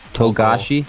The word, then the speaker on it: Togashi: Togashi.